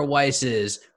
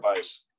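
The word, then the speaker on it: Weisse: Oh, just so you know, it's pronounced Weiss, guys. Just so you know, Berliner Weiss. – Is it? – You guys have probably been saying Weiss your whole life.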